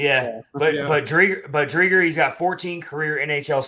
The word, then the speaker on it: Driedger: But Chris Driedger only has 14 career NHL starts. So he's definitely – or Driedger. I should say Driedger. Correct pronunciation there.